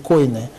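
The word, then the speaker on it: койне: то есть на язык койны. Или кини, как по-разному произносится это слово, и коины, и кини.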